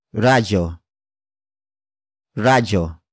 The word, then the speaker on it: radyo: Radyo. Radyo.